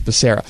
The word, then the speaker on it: Becerra: He still said it wrong. It's Becerra. Anyway, he was on MSNBC to explain why he continues to wear a mask, even though he's fully vaccinated.